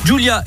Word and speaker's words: Ghjulia: Julia est donc née le 2 novembre 2020 et son prénom s'écrit à la Corse comme son papa. Donc ça s'écrit G-H-J-U-L-I-A. Bienvenue donc à la petite Julia.